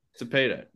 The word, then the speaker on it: Cepeda: I got I'm going with Orlando Cepeda, is that how you say it? – Is that right? Or Cepeda. – Cepeda, yeah. I'm not Orlando Cepeda.